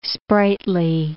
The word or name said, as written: sprightly